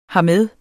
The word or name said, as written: hermed